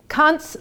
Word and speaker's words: Kant: And my husband also said to me, be very careful how you say his name. You should say Kant. So Kant's Little East Prussian Head and Other Reasons Why I Write. In America, we don't say Kant, though, because we try to want to sound European.